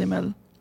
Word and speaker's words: mall: How would you pronounce M-A-L-L? – M-A. So, okay. Personally, I'll say mal. – Yeah, okay. – I would not say mel.